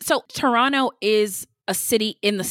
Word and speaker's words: Toronto: And it's pronounced Toronto. That's what the natives say.